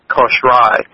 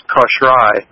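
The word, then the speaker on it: Kosrae: we were in particular on the island of Kosh Rai, and it's spelled kind of funny, and the pronunciation is different, but it's K-O-S-R-A-E, and it's pronounced Kosh Rai, and it is kind of, in the Federated States of Micronesia, it is the last island in the chain.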